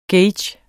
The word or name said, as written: gauge